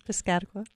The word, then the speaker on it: Piscataqua: Is it the Piscataqua River Bridge? – Piscataqua.